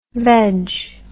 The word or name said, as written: vedge